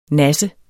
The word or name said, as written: nassende